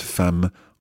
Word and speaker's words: femme: Car il a fait beaucoup de recherches, because he's done lots of research, sur cette femme emblématique, on this symbolic woman. – Yeah, and watch the pronunciation of femme. Okay, it's quite a tricky word. – Femme, F-E-M-M-E, femme.